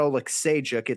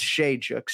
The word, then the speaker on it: Oleksiejczuk: You know, it's Michal Oleksiejczuk. It's not Oleksiejczuk. It's Shejuk. So syllable, syllabic emphasis was pretty good, but that's 0-1 for me, Ken Flo.